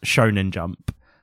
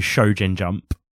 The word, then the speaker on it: Shonen Jump: I actually made Ryan correct this in the episode, the last manga episode we did, because I consistently mispronounced Shonen Jump. I pronounced it as Shojin Jump.